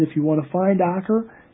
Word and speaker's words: Acre: which could be mispronounced Acre, but it's Acre.